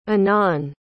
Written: anan

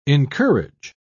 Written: encourage

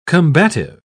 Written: combative

